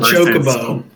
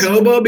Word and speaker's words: Chocobo: It's called a chocobo.